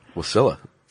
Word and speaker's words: Wasilla: Wasilla.